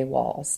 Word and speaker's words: Wahls: It's spelled WAHLS, Dr. Terry Walls, TERRY.